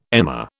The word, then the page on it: emmer: British English